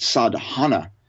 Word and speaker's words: sadhana: This comes from the Sanskrit language. It's pronounced sadhana.